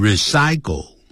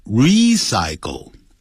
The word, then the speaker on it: recycle: Recycle, 要强调要发 E 的长元音 to recycle something, 就是把某个东西废物利用啊，回收利用，那个 re 就是再一次的意思，所以不能念成 recycle, 叫 recycle, 啊，发 E 的长音 recycling, 也就是这边是一个动名词，当标题废物回收利用。